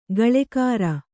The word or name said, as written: ಗಳೆಕಾರ